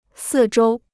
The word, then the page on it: 四周: sì zhōu